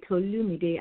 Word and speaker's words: Tolumide: Instagram, it will be Tolumide, and I'll spell it out: is T O L U M I D E. Okay, Tolumide, and my name is pronounced Tolumide.